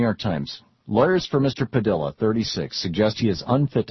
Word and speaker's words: Padilla: I know some people pronounce it Padilla. That's how you would think it should be pronounced, but he has asked that it be pronounced Padilla.